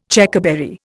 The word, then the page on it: Checkerberry: Indian